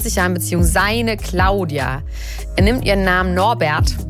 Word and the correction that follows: Norberg: Norberg. Norbert. Norberg. – Du kannst das nicht immer falsch aussprechen.